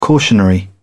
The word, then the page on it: cautionary: British English